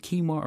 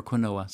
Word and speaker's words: quinoa: There's two different pronunciations. I remember going to a health food shop and said, "You're pronouncing that wrong." But then another person said, "No, you can pronounce it quinoa or quinoa."